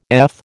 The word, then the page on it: eth: American English